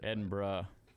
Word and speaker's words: Edinburgh: From Warsaw to Edinburgh, or I don't know how you say it. – Edinburgh.